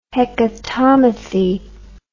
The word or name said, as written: Hecatommithi